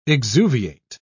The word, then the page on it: exuviate: British English